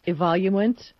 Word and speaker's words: Evoluent: So, and yeah, because I, everybody's different, like the mouse that you had, I don't know how it's pronounced, evoluent. Yeah, vertical that mouse. sounds right to me.